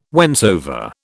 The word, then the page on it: whensoever: British English